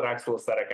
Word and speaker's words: oxaloacetic: I call it oxaloacetic acid. – Right. Yeah. – I call it that's how I've been calling it for the last 7 years. – Yeah. – Well, that's how you say it.